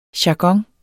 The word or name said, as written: jargon